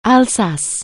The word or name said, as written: Alsace